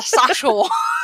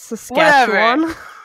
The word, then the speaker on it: Saskatchewan: It's a Saskatchewan. – Okay, that's not. Are you serious? Think that's how it's pronounced? – I don't know. – It's Saskatchewan.